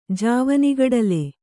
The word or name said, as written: ಜಾವನಿಗಡಲೆ